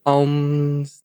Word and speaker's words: Omens: Aliás, eu não sei a pronúncia direito. Como é que é? É Omens? Como é que é? Good Omens? The Good Omens. – Uh -huh. Omens.